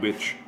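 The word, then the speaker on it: Bubic: You tell me how to pronounce that, right? – Bubich. – Okay. It's very Eastern European of his last name. – Right. – Which is the C, but it's pronounced shh.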